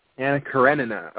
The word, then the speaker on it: Anna Karenina: Anna Karenina.